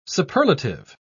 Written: superlative

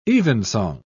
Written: evensong

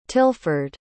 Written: tilford

